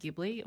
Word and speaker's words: Ghibli: Is it Ghibli? Oh my gosh, I'm going to get the name wrong. – Yeah, Ghibli.